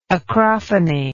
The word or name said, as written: acrophonetically